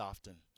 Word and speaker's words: often: Often. The T is silent. – Or the voice of logic and reason. – I'm right. I'm going to punch him. – The T is silent and often. It's not often.